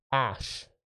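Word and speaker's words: Äse: The goddess of life, fertility, and earth, Ash. It's spelled A-S-E in the document. But the A's got those two little dots above it, so it changes the pronunciation a little bit.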